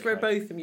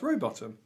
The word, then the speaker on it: Rowbotham: I would imagine there are people today that will try and pronounce it Rowbotham, but traditionally that was always Rowbottom.